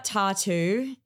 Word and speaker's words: t.A.T.u: No, I'm not mispronouncing tattoos. I'm talking about t.A.T.u, the Russian pop duo from the mid-2000s.